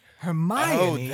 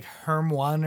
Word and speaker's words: Hermione: Hermione. – Oh, that's how you say her name. Okay. – Hermione. – Hermione? – Yeah. I think I even like called her like Herm One or something like that.